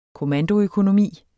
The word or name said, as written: kommandoøkonomi